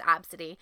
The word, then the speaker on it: Abcde: This woman who named her kid Absidy, spelled A-B-C-D-E, pronounced Absidy.